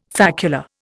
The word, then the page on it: facula: Indian